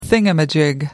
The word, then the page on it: thingamajig: British English